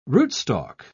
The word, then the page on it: rootstalk: British English